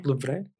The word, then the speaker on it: Louvre: Lavrock, no recuerdo cómo se pronuncia. Louvre. – No, pues se pronuncia diferente. nuestros amigos que saben francés.